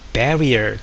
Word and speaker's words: barrier: これ日本語でバリアを張るなのバリアっていうのは発音は barrier っていう発音ですが、障壁とかですね。